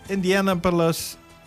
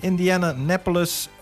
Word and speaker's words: Indianapolis: En even kijken, nummer 9 van de 54 is in 1965 door toenmalige Daimler-Benz AG geschonken aan het Indianapolis. Hoe spreek je dat ook weer? – Waar staat hij? – Indianapolis.